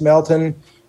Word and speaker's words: Melton: Mr. Chairman, members of the committee, for the record, I'm Lance Melton, Executive Director with the Montana School Boards Association. My last name is spelled M-E-L-T-O-N.